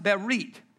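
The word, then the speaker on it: berit (ברית): It's pronounced berit.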